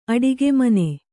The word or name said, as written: ಅಡುಗೆ ಮನೆ